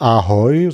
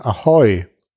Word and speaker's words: Ahoi: Und die Betonung liegt auf der zweiten Silbe, also nicht Ahoi, sondern Ahoi.